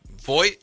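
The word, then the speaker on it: Vogt: Now, we are in the midst of Steven Voigt, is that how you say it? – Yes.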